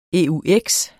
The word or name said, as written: eux